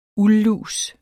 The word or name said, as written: uldlus